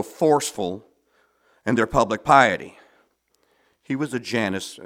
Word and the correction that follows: Jansenist: Jansenist. Jansen. He was a Jansenist.